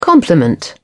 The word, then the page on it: compliment: British English